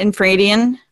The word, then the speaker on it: Infradian: No, well, because you know the Infradian river. Did I say that right? Infradian. Mm-hmm. Yep.